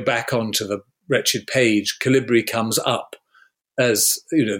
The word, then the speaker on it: Calibri: The default font is, I don't know how you pronounce it, Calibri or Calibri? – Calibri, Calibri. Thank you.